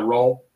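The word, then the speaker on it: Ayrault: Can you pronounce it? it's A Y R A U L T? Brian uh, Roll.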